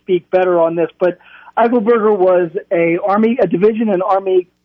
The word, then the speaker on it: Eichelberger: Did I say that right? Eichelberger. Tell us about Eichelberger. – Him. Robert Eichelberger. Go ahead. – You got it.